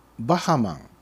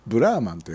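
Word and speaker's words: BRAHMAN: で 17 位がバハマンあバハマンじゃないブラーマンと読むのこれ、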